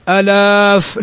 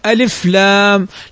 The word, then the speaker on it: ألف: هذه مجموعة في قولك حي طهر، وهناك حرف لا يمد أصلا إنما ينطق به هكذا وهو الألف، فنقول ألف لام، ما نقول ألاف لا، ألف لام.